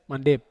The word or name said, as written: mandep